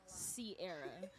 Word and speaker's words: Cierra: Crystal Sierra. And Crystal is spelled with a C, and Sierra is spelled C-I-E-R-R-A.